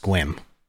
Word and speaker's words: Sequim: So that's actually pronounced squim. – Squim? – Squim. Yeah.